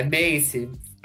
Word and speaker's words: Macy: Nossa, tá complicado, hein? É Macy. Né? Pronuncia do jeito que quiser.